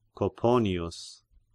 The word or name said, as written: Coponius